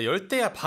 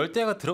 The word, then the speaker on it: 열대야: 이런 거 같긴 한데 아, 열대야입니다. It's right, pronounced right. with like a double T. – Mm-hmm. Yeah. – 그리고 열대야가 들어간 문장을 한번 만들어 봅시다.